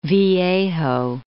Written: Mission Viejo

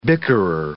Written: bickerer

